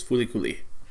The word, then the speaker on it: FLCL: It's spelled FLCL, but in English it's referred to as Fuli-Kuli.